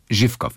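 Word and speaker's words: Schiffkow (Живков): Schiffkow, nicht Schiffkow, nicht scharf ausgesprochen. Schiffkow, Schiffkow.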